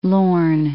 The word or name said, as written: Firth of Lorne